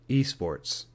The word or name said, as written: esports